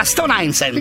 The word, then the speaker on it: Stone Island: Questi gym della Stone Islands. – Stone Island. – Ma perché scrivono? Scrivono come si legge. – Si chiama così, Stone Island.